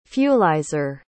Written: fuelizer

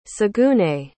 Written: saguenay

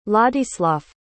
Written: ladislav

